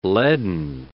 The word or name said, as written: leaden